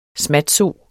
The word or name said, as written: smatso